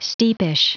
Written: steepish

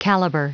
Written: caliber

